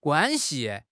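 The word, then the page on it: guan1 xi5: tone 1; tone 5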